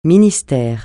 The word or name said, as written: ministère